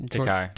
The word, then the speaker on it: Takei: Takai.